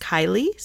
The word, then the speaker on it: Caeli: I'm going to use three different songs from his album called, I think it's pronounced Kylie, C-A-E-L-I,